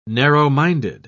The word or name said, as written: narrow-minded